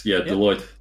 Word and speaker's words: Deloitte: I'm not sure about the exact pronunciation, Deloitte Digital? – Yes, Deloitte, yep.